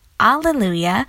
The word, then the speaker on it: Alleluia: In Greek, there are no H or H sound, so they use it as the closest sounding one, which is A or A. That is how Hallelujah became Alleluia and is pronounced the way it is.